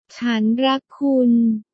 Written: chan rak kun

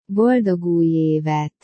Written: boldog uj evet

